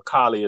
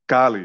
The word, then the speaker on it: Cali: Okay, so how you pronounce it is just based on your language. So, and of course, in Spanish, Cali is Cali because of the way the A is pronounced. Us, right. In the United States, you know, it's Cali, you know, like California, like that's how we pronounce our A.